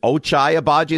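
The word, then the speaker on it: Ochai Agbaji: Is it Ochai Abaji? Is that how you say it? – I believe so, yeah.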